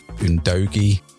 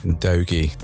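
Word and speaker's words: Udogie: And then the left back is pronounced Ndogi. – Proper pronunciation, that. – Ndogi, yes.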